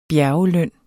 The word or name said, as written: bjergeløn